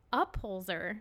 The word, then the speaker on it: Upholzer: You said my name wrong. – Upholster. – No. – What is it? – T Boyage. Upholzer. – Upholzer. What did I say? – Upholster. – Like upholstery? I said upholster? – Yes.